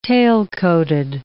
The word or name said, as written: tailcoated